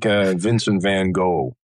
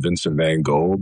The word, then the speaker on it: Vincent van Gogh: But it's like Vincent van Gogh. Everyone calls him Vincent van Gogh, but if you're in Holland, it's Vincent van Gogh. It's a little bit of a different pronunciation, but it's Inga, pretty common name in the province of Friesland in Holland, but not so common over here.